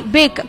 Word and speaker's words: Beck: Ashley Beck, como Beckham, pero diferente pronunciation. – No, diferente escritura. – Ah, bien, mira. – Es como Beckham.